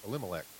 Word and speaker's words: Elimelech: A man named Elimelech, I'm sorry, I already said his name wrong. No, I said it right, Elimelech.